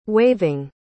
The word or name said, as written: waiving